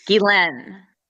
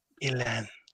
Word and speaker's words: Ghislaine: Gilen. – Gilen. – Okay, so imagine like ghee, like the butter, and Len. Len. Not Len. Lane, not Len, Len.